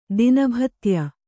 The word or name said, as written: ದಿನಭತ್ಯ